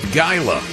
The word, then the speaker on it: Gila: and we spent the night in Gila Bend, which is 700 people. – I believe it's pronounced Gila. Gila Bend. Like the Gila monster, the lizard.